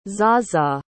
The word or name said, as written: zaza